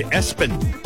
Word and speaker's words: ESPN: One name was ESPN, and they said Espen.